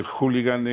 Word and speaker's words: hooligan: Bueno, fíjese usted si Ignacio de Hoces es fino, que pudiendo llamarle jabalí a Óscar Puente, le ha llamado hooligan, que es una cosa como de club londinense. – Más moderna, más moderna. – Bueno, es trisílaba, solo que hooligan es esdrújula y jabalí es aguda.